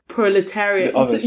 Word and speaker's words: proletariat: Proletariat.